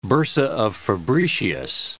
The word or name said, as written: bursa of Fabricius